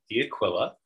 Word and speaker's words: D'Aquila: So my name is Mark D'Aquila. Um, it's Italian, and I'm probably not pronouncing it properly.